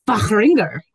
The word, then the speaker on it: Fahringer: Fahringer.